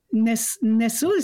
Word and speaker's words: Nestle: Il y en a une autre qui, ça s'appelle pas Marion, mais elle s'appelle Marion, Marion Nestle, ça s'écrit comme Nestlé.